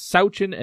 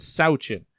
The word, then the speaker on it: Sawchyn: And I know, because I double-checked with him, I said, did I hear you pronounce that right? That it's Sawchyn and not Sawchyn? And he said, yes. So there it is. It is Gracyn Sawchyn.